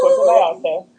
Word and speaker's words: Puerto Vallarta: He wanted so badly to go to Puerto Vallarta, if I said that right.